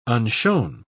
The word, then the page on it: unshown: British English